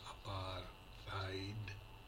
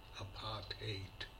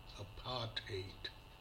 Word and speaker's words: apartheid: This word is most often mispronounced as apartheid in the subcontinent. If you wish to pronounce it the way it is pronounced by native speakers, say apart and hate in quick succession without any pause in between. Say apartheid. The stress is on the second syllable part, so say apartheid.